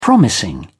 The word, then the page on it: promising: British English